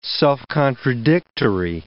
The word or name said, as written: self-contradictory